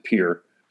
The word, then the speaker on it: Pierre: Pierre, South Dakota, North Dakota, and some of the surrounding states, they pronounce it Pierre.